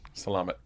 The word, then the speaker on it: Salamat: Salamat. – Salama. – With a T. – Salamat. – Salam alaikum. – Assalamu alaikum? – I don't know that. – Is it from Salamat?